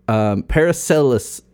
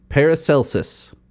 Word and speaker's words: Paracelsus: Let me see if I can say his name right. I actually went and listened. I'm like, how do you pronounce this? And like played the thing. Paracelsus. No. Paracelsus.